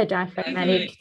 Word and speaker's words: diaphragmatic: And it's so interesting when you start to check in with diaphragmic breath. Am I saying that right? – Yeah, diaphragmatic. Yeah.